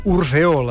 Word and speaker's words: Orfeó: és a dir, diem Orfeó, la primera neutralitzada, atonitzada, i les segones plenes, per dir-ho així, Orfeó.